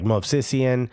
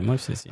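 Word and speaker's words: Movsisyan: And, you know, I look at guys like Joao Plata, and I look at guys like Movsisian. Mm-hmm. I'm not even going to pretend to say his first name. – You said it right, Movsisian.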